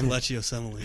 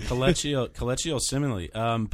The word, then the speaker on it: Kelechi Osemele: I totally agree with the Asalimini me guy. I can't say his name. – Kelechi Osemele. – Kelechi Osemele.